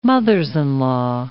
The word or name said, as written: mothers-in-law